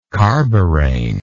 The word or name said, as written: carborane